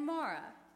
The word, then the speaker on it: Mary: We know them as Martha and Mary, but in their own tongues, they were probably pronounced Martha and Mara.